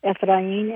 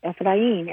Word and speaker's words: Efrain: He pronounces his name Efrain, and the bigger point about that is that we need to respect how people pronounce their own names and not respect that by saying, this is Boulder. All of the vowels in Efrain's name exist in the English language, so there is no mystery nor talent needed to pronounce his name.